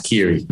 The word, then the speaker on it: Kearney: It's pretty close. Kearney. It's pretty hard to pronounce. Kearney.